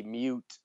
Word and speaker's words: miut: but it phonetically spells out M-I-U, mew. – Mew. – Yeah. – Yeah, their word for cat was M-I-U-T, mute, and